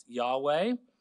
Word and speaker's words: Yahweh: These three features are, number one, the name of the God, Yod-Heh-Vav-Heh, often pronounced Yahweh.